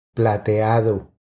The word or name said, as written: Plateado